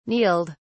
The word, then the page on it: kneeled: British English